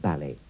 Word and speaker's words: ballet: In Great Britain, the stress is on the A of ballet, but sometimes Americans put it on the E.